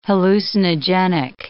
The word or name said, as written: hallucinogenic